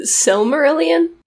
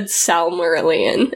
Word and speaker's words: Silmarillion: It sounded like you said Salmarillion, and I was like, yeah. Pretty much. – I know in my head that it's an I.